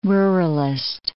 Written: ruralist

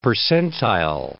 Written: percentile

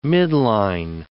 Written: midline

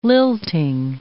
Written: lilting

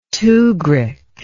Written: tukhrik

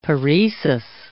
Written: paresis

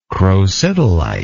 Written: crocidolite

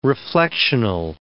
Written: reflectional

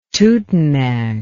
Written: tutenag

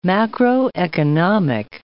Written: macroeconomic

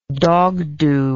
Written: dogdoo

